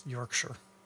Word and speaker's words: Yorkshire: Don't say Shire. It's Yorkshire.